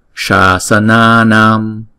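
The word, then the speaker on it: śāsanānām: Namma Sammanta Buddhanam Apratihata, Apratihata Shasananam. Sha, chữ S này là đọc là sha, ển cong cái lưỡi lên và đọc là sha, sha, sa, na, nam, thấy không?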